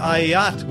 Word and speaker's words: Ayat: Linda Ayat, which rhymes with a yacht, for your generous support of the podcast.